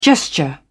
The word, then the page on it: gesture: British English